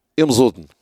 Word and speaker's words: Imzoudn: Les Masmouda, si je la prononce comme ça, vous n'allez rien comprendre, c'est Imzoudn.